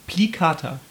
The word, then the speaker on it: plicata: Plicata. – Mhm. – Ja. – Okay. – Na, du musst das Spanisch aussprechen. Plicata.